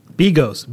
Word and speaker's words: bigos: Bigos, B-I-G-O-S.